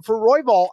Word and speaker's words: Royval: And the way I see it, it's basically like the fundamentals, the consistency of Moreno versus the chaos of Royval. So, yeah, did I say that right? Royval? Yep. Okay.